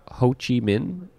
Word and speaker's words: Ho Chi Minh: You're from Ho Chi Minh, is that how it's pronounced? – Yeah.